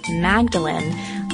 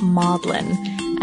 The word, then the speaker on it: Magdalen: In our Oscar Wilde podcast, we said the Oxford College of Magdalen, like Mary Magdalene, it should be pronounced Maudlin and she says it's another little Oxford oddity.